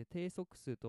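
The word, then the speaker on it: 定足数: 定まる足の数と書いて定速数と読む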